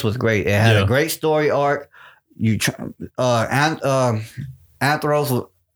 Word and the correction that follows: Andros: I'm saying his name wrong. Andros, that's Andros. How you say his name.